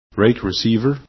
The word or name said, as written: rake